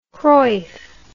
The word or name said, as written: cruyff